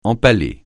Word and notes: empaler: francais

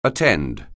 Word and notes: attend: anglais

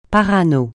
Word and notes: parano: francais

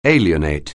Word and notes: alienate: English

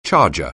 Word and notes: charger: anglais

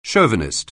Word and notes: chauvinist: anglais

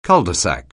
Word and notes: cul-de-sac: anglais

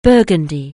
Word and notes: Burgundy: anglais

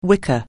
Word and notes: wicker: anglais

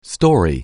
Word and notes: story: anglais

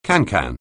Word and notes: cancan: anglais

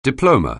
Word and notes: diploma: anglais

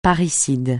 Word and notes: parricide: francais

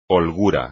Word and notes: holgura: espagnol

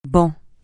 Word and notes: ban: francais

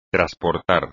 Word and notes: trasportar: espagnol

